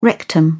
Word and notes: rectum: British English

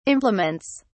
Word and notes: implements: British English

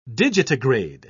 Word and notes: digitigrade: British English

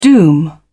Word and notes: doom: British English

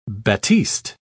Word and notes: batiste: British English

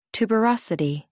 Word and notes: tuberosity: American English

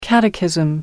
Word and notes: catechism: American English

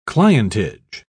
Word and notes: clientage: British English

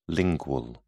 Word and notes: lingual: British English

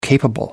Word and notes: capable: American English

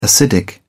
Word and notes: acidic: British English

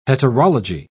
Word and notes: heterology: British English

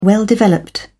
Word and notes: well developed: British English

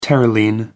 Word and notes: terylene: American English

